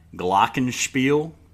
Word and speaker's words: Glockenspiel: But it's got Glockenspiel. Mm-hmm. Did German say that right? – You did.